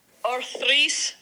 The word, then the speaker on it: Othrys: Othrys Farms.